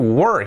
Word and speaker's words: were: Una es where, dónde, y otra were. Where, were. Where, were. Where son dos sílabas, we-er, dos sonidos, mejor dicho. We-er, we-er, we y el sonido de R. Where, where, dos sílabas bien separadas. Y este es uno, were.